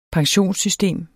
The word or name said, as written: pensionssystem